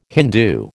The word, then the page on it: Hindoo: American English